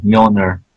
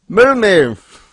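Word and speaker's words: Mjölnir: True. Mjolnir. Unpronounced. – Yeah, you got it right. – Oh, cool. I got it. – You got it better than some of the actors in the movies. Mjolnir.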